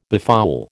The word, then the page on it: befoul: American English